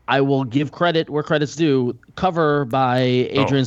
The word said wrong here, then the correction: Ardian: Sayaf is great. – Great in the context of today's superhero comics artists. – Ardian. – Oh, look at that. Okay. I've been mispronouncing that his entire life.